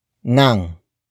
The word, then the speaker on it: ng: The next word is nang. Nang. Nang. Nang is spelled as ng.